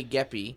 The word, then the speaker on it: Geppi: Geppi, G-E-P-P-I, so you tell me how that's pronounced. – P-P, how you said P-P.